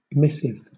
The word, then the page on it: missive: British English